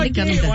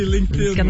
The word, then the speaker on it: LinkedIn: Ça se prononce de la même manière. – À peu près. – Tu rajoutes un D entre le N et le LinkedIn. Ouais, c'est LinkedIn.